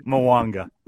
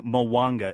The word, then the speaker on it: Mwanga: It sounds it sounds like Mwanga. Yeah, and just the way it's like the two syllables, Danny Mwanga. It almost is like pop, pop, pop.